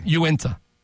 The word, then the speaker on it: Uinta: But Uinta. – Uinta? – Yeah. – It's not Unita.